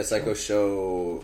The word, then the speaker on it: Psycho Pshow: Yeah, Psycho Show with a silent P, people. Psycho Show, silent P in both the Psycho and the Show. So, Psycho Show.